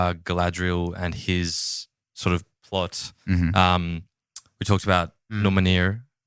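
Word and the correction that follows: Númenor: It's pronounced Numenor.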